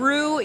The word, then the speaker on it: Roux: And Roux, you guys, is spelled R-O-U-X.